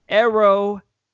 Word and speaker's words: Io: It's not Io. She doesn't pronounce it Io. It's spelled Io. I want every tweet this man. It is Ero Shirai.